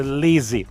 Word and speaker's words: Lzzy: Кстати, она по, каким- по каким-то причинам букву I из своего имени вынула, пишется L, Z, Z, Y, LZ читается Лизи.